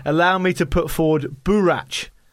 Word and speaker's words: boorach: It's spelled B-O-O-R-A-C-H, anyway. Anyone wants to get that one. – Burach! There you go. That sounds about right.